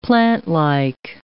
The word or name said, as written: plantlike